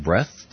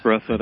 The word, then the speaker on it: Breathed: It looks like George Bush has even inspired Bloom County cartoonist Burke, is it Breathed? – Burke Breathed, I believe. – Breathed, I never knew how to pronounce it, to come out of retirement.